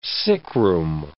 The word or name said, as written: sickroom